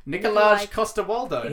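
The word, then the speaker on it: Nikolaj Coster-Waldau: Nicolaj Coster-Waldau.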